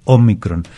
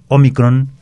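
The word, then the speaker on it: ómicron: Y dado que su pronunciación es Omicron, es una palabra esdrújula y todas las esdrújulas van acentuadas.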